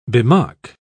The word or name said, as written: bemock